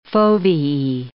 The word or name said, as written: foveae